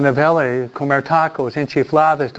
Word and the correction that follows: enchiladas: Oh, enchiladas, siempre lo digo. Siempre lo digo mal.